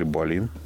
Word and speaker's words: Болин: В 1959 году шведский инженер Нильс Болин, или Болин, извините за ударение, разработал улучшенную версию трехточечного ремня безопасности, которая не только обеспечивала высокую безопасность, но и была удобной для повседневного использования.